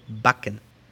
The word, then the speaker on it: backen: Por ejemplo, hornear se dice backen. B-A-C-K-E-N. Backen. Aquí la A es corta.